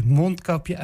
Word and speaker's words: mondkapje: Iedereen weet dat je mondkapje uitspreekt met het accent op de mond. Klemtoon plat op de bek, zoals in Twente zeggen.